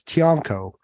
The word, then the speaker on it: Tionco: which is Tionco. – Yeah. – But sometimes, like the Filipinos, they pronounce it Chonco. Yeah. So kind of like CH.